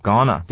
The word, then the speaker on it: going to: 再来是 going to 呢，各位都知道应该念作 gonna，对不对哈？What I'm gonna buy。